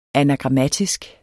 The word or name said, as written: anagrammatisk